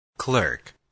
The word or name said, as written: clerk